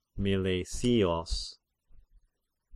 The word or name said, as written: Milesios